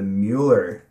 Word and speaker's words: Mueller: So we are talking about the Mueller neighborhood.